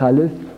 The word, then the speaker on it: aleph: En réalité, l'aleph lui-même est muet. On ne peut pas le sonner. On ne peut pas prononcer l'aleph. Pour dire la lettre aleph, on ouvre la bouche.